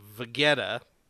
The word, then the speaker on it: Vegeta: I found the video and it is Blue Suit, so it's Vegeta.